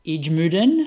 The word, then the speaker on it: IJmuiden: The town is Ijmuiden. Is that how you'd pronounce it, boys? – Yep, good work.